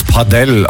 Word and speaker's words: Padel: Es ist wohl Spanisch. Man soll es Padel aussprechen.